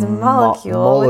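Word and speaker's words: Molekule: It's molecule with a K.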